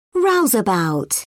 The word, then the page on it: rouseabout: British English